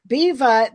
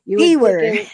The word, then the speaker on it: Biewer: Now, Beva, that's pronounced Beva, but it's spelled B-I-E-W-E-R. – Beaver. – Beaver is how I would have said it. But I was corrected and I was instructed that the correct pronunciation is Beva, like diva with a B.